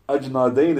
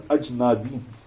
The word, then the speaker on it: Ajnadain: In Arabic, it's pronounced Ajnadin. But we're trying to use a bit more of the English version of events.